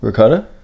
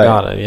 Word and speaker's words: ricotta: It sounds like you're putting a G in it. – I probably was, yeah. – But you were doing the same thing downstairs. – Right. Regatta, yeah. Regatta. – There's no G in that.